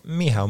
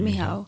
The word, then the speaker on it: Michał: I say, my name is Michał. Ask me how to pronounce my name. So it's just M-E-H-O-W.